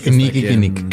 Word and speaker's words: Kinnickinnic: El 6 de septiembre de 1986 visitó el río Kinikinik. – Sí, es el Kinikinik. – K-I-N-N-I-C-K-I-N-N-I-C.